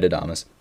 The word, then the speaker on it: Adames: I mean, they have Willy Adames. I don't even know how to say his last name, but it's Willy. – Yeah, no, you got it right, Adames.